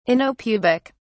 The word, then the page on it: anopubic: British English